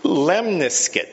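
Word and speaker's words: lemniscate: Right, the term for that is, it rhymes with biscuit. Lemniscuit. So that's the term.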